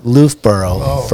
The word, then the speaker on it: Loofbourrow: Loofbourrow.